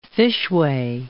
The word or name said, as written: fishway